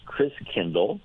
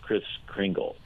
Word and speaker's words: Christkindl: And the German pronunciation of Christ child was Christkindl, like kindergarten, kindercare, right, means child. And over the years, Christkindl got pronounced Kris Kringle. – Huh. – So Kris Kringle is really Christkindl, which means Christ child.